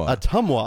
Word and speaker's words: Ottumwa: And then the very next week, we are going to be road tripping up to, and I'm going to pronounce it correctly now. – Yes, please inform me. – Ottumwa, Iowa.